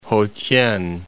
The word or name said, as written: Ho-t'ien